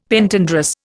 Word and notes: Pentandrous: Indian

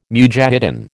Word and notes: mujahidin: American English

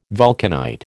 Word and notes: vulcanite: American English

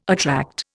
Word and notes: attract: Indian

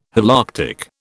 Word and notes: Holarctic: British English

